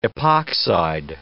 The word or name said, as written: epoxide